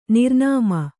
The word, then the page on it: ನಿರ್ನಾಮ: nirnāma